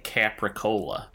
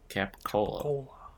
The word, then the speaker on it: capicola: It sounded like a food item, and I thought it was a cheese, but I realized that Coppola sounds like Capricola, the kind of ham. – Capicola? – Capicola. – The R is not pronounced, at least not in the Bostonian dialect of English.